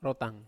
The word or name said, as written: rotang